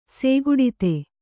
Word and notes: ಸೇಗುಡಿತೆ: sēguḍite

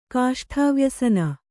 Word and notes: ಕಾಷ್ಠವ್ಯಸನ: kāṣṭha vyasana

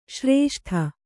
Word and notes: ಶ್ರೇಷ್ಠಿ: Kannada

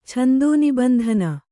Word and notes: ಛಂದೋನಿಬಂಧನ: kannada

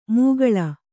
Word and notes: ಮೂಗಳ: mūgaḷa

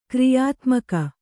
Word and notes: ಕ್ರಿಯಾತ್ಮಕ: kriyātmaka

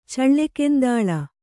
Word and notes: ಚಳ್ಳೆಕೆಂದಾಳ: Kannada

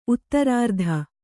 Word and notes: ಉತ್ತರಾರ್ಥ: uttarārtha

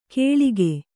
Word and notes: ಕೇಳಿಗೆ: kēḷige; Kannada

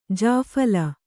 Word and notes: ಜಾಫಲ: jāphala

